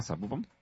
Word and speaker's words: Zapopan: Ah, Zapopan. – Sí, con acento en la segunda. En la segunda sílaba, en la O, ¿cierto?